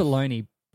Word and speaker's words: bologna: Bologna, but it's not spelt bologna. It's not spelt how it sound.